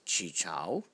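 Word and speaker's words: Chee Chau: It's Chi Chao.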